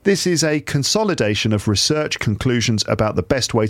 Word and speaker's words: research: Now, you research, this is a noun. The noun can be pronounced research or research. There's two ways to pronounce the noun. The verb is always pronounced research, to research something.